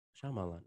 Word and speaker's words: Shyamalan: I think you said it Shyamalan. – Right. Shyamalan.